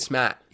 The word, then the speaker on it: Smaht: But at the end of War Room, they had this competition called Wicked Smart. Wicked Smat. You don't pronounce the R.